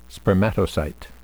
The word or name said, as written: spermatocyte